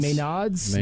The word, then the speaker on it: Maenads: I can never pronounce their names. – So, Maynards. – Maynards. – Maynards. – Maynards. You just said Maynards, exactly.